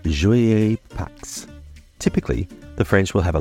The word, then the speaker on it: Pâques: In France, Easter is pronounced Pax, and spelt P-A-Q-U-E-S.